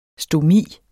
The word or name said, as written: stomi